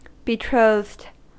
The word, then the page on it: betrothed: American English